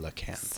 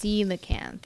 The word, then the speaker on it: coelacanth: So that's a C that sounds like an S. – Indeed, it is. – Coelacanth. – Coelacanth. And an O E, that sounds like an E.